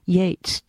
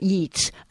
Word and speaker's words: Yeats: it's pronounced Yeats not Yeats